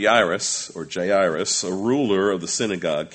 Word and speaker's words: Jairus: His name is Jairus. That's how we pronounce it, so I'll call him that, Jairus.